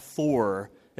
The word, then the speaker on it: Foor: It's actually F-O-O-R, four, as opposed to F-O-R-D.